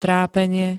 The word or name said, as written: trápenie